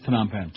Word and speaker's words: Phnom Penh: You know, there was always quite a controversy about that, about how you pronounce that. Phnom Penh or Phnom Penh. But anyway, it was Phnom Penh.